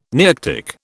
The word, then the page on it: Nearctic: British English